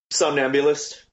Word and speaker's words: Somnambulist: Now, if I say this right, the Somnambulist. – Sure, mm-hmm. Right Enough. – Is that right enough? What is it? – Yeah. Somnambulist.